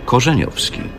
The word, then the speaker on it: Korzeniowski: Korzeniowski.